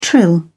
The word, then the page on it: trill: British English